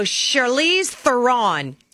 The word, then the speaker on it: Charlize Theron: So I'm just going to go Charlize Theron.